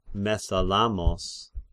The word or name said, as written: Mesalamos